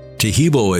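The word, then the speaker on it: Taheebo: Tahibo is spelled T-A-H-E-E-B, like boy, O, then the word tea, and then the word club.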